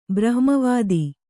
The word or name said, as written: ಬ್ರಹ್ಮವಿದ